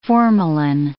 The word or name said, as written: formalin